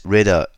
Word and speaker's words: Rueda: the region called Rueda, if I'm using a little more English pronunciation. It's R-U-E-D-A.